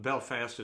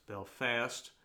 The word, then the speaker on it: Belfast: It's not Glasgow, it's Glasgow, if I'm saying it right. Just like it's not Belfast, it's Belfast.